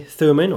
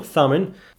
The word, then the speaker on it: Thummim: Urim or Urim, it can be pronounced differently, Thummim or Thummim.